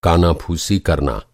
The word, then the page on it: काना फूसी: کانا پھوسی